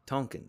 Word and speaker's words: Tonkin: Um, I still can't pronounce that word. Tonkin. There you go.